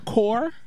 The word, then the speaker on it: corps: Core kills me. C-O-R-P-S. – Yeah. – Kills me. Just C-O-R.